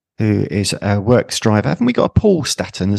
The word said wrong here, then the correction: Stratton: as well? – Yeah, Paul Stratton. – Yes, Stratton. Sorry. Yeah. I'm just pronouncing it incorrectly. It is Stratton.